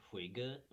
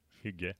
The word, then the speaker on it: Hygge: Hygge. Yeah. – Huga. – Do you know it? – Yeah, yeah. – I just Googled it. – Yeah, it's Hygge.